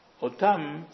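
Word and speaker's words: Otam (אותם): Donc ça se lit quand même Otam, mais ça s'écrit de telle sorte qu'on puisse le lire aussi, et ce serait la première lecture, si vous avez Aleph, Tav, Mem, vous allez lire ça à Tem. Pas Otam, mais à Tem.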